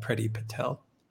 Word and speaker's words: Priti Patel: We have a video coming out most likely tomorrow on Boris Johnson and Priti Patel. Pronouncing that right, Alexander? – You are indeed.